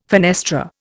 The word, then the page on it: fenestra: Indian